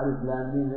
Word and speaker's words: الم: تنطق بأسمائها تنطق في القرآن بأسمائها مكتوبة عندكم إيش؟ من مكتوبة بعض الجهال يقرأ ألف لام ميم إيش يقرأ؟ إيش؟ ألف يجينا ركبها ينطقها كلمة مركبة من الأحرف الثلاث.